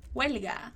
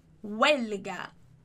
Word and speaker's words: huelga: Sal tu de la parada de taxi que los taxistas están en huelga. Muito cuidado para a pronúncia dessa palavrinha. Lembra que o H ele não vai ter som. Então, huelga. Huelga.